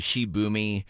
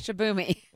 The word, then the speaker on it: Shibumi: You know, I don't think this would have happened if she had one of those fancy Shibumi tents. Have you seen Shibumi? Shibumi. – Am I saying it wrong? – You are definitely saying it wrong.